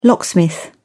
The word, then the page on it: locksmith: British English